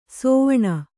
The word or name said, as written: ಸಾವಣ